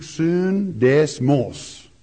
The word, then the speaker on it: sundesmos: S-U-N-D-E-S-M-O-S. Soon, pronounced in the Greek, soon desmos.